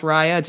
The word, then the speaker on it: Raya: It's pronounced Raya, just FYI, but not that it matters.